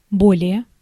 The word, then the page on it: более: /ˈbolʲɪjə/; boleje